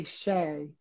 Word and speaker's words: chai: It, so we say shay. So that it's not a ch. I guess it's a SH, Shay.